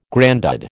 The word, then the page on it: grandad: American English